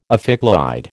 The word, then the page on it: ophicleide: American English